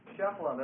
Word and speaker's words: Shephelah: Shephela, that's spelled S-H-E-P-H-E-L-A-H, the Shephela.